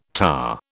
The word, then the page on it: TA: British English